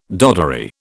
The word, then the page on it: doddery: British English